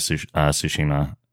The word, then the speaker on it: Tsushima: I heard an interesting comment on Ghost of Tsushima, and I don't even know if I'm saying it right. – You are.